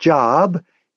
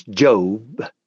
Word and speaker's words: Job: His name is spelled the same as our present-day word Job, J-O-B, but it's pronounced Job.